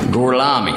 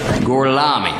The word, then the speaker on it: Gorlami: Gorlomi, lo pronuncio corretamente? – Sim, correto. – Gorlomi? Per cortesia, me lo ripeti ancora. – Gorlami. – Scusi, con me? – Gorlami. – Ancora una volta. – Gorlami.